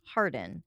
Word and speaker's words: Harwarden: and his true name is Thomas Harwarden, H-A-R-W-A-R-D-E-N, pronounced Harden.